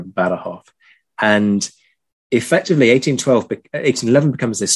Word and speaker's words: Badajoz: This is exactly where we get Ciudad Rodrigo and Badajoz. I've just done that thing of going, here's the Spanish pronunciation, and I'm going to be hip and millennial and use the Spanish pronunciation.